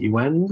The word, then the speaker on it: Yuen: Look for Anthony Yuen. Last name is spelled Y U E N.